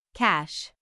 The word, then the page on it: cash: /kæʃ/